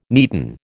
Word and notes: neaten: American English

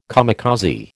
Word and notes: kamikaze: American English

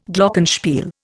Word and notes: Glockenspiel: Indian

